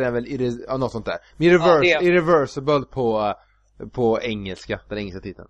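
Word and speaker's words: Irréversible: Och då har jag i filmen Irrevestible heter den. Jag är dålig på att uttala det franska. Vet du vilken film jag menar? Med Vincent Cassel och Monica Bellucci. – Ja, Irreversible menar du.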